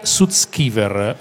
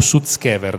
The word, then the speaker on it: Sutskever: Ilya Sutskever, o Sutskever, dipende se lo pronunci alla russa o all'americana.